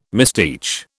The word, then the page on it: misteach: British English